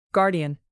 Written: guardian

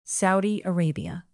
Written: saudi arabia

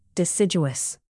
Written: deciduous

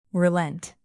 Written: relent

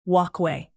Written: walkway

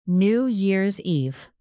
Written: new years eve

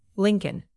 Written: lincoln

